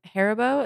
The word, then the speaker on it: Haribo: Haribo, is that how you say it? – Yeah, that sounds right to me.